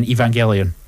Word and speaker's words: Evangelion: But we also got Year 2 Godzilla is apparently the first one coming, and then Evangelion.